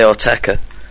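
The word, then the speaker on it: Autechre: However you like. Different. Yeah. I say Oteka.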